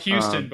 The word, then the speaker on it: Houston: Which is spelled Houston, but you say it Houston.